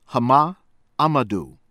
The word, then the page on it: Hama Amadou: from Niger